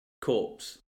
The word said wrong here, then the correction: corps: Core. – So it's the nurse corps? – Yeah.